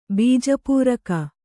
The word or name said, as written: ಬೀಜಪೂರಕ